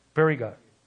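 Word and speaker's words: Phrygia: It says, Parthians and Medes and Amalekites, those dwelling and Mesopotamia, Judea and Cappadocia, Pontus and Asia, and Perigia. Well, how do you say that? Pronounce that name? Periga. Yeah, you got it.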